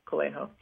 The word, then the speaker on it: Coelho: Yeah, Coelho.